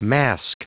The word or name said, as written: mask